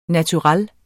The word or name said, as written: naturel